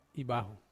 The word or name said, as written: baho